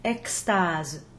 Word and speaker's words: extase: In the case we have here, initial EX is followed by a consonant, so we pronounce that X as an unvoiced KS, extase.